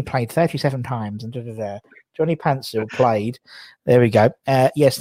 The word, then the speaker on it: Paintsil: And his name, because it was like Pansil, but he'd spell it Paintsil, and there was no one ever knew how to spell his name properly.